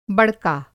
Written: बड़का

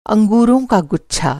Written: गुच्छा

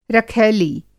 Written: रखैली

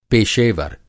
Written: पेशेवर

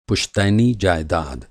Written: जायदाद

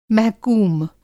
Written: महकूम

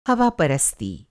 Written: हवापरस्ती

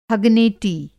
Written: हगनेटी

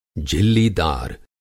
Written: झिल्लीदार

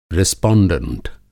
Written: रेसपांडेंट

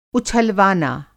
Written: उछलवाना

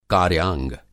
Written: कार्याग

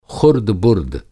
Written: ख़ुर्द-बुर्द

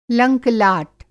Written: लंक लाट

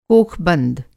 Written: कोखबंद

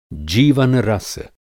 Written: जीवन-रस

